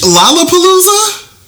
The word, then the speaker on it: Lollapalooza: Bitch, Lollapalooza? – Who was that? Do you know who that is? – You said LOL Apalooza. – But look how it's spelled.